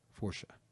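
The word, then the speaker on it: força: A-L-A-V-A-C-A. Alavanca. – Interesting. – Yeah. – Portuguese is fascinating. Because it's actually pronounced Forsha. – Forsha. Oh, Forsha. – Because, you know, the little accent on the C, and I may be mispronouncing that, but it's in the neighborhood.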